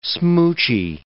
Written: smoochy

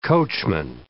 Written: coachman

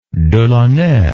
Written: Delaunay

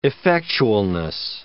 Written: effectualness

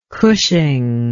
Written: cushing

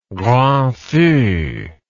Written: grand feu

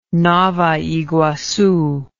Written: nova iguacu

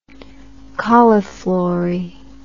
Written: cauliflorous

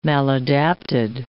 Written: maladapted

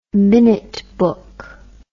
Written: minute book

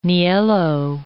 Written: niello

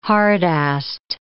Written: hard-assed

